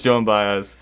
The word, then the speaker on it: Joan Baez: I know it's Joan Baez.